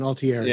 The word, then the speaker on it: Altieri: You got to say it like you're Italian, Altieri.